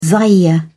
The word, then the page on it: zaire: British English